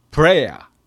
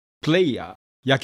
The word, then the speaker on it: prayer: Prayer 祈り、祈る R の音で Pray、これに ER がついて人を表す、あるいは祈りで、この場合の発音が Prayer Air という音になります。よく間違えるのがプレイヤー、野球の選手、バスケットの選手、あるいは俳優。